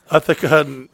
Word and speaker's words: Ithaca: Brock Kuhn, K-O-O-N-E. – Boy. – Bridgetown, Washington. Michael White from Ithaca. – Euthanasia? – Ithaca, New York. That's how you say it.